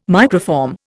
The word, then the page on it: microform: Indian